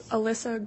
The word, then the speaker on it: Alyssa: Alyssa Greenhalch, A-L-Y-S-S-A, and the last name is G-R-E-E-N-H-A-L-G-H.